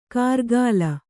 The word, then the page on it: ಕಾರ್ಗಾಲ: kārgāla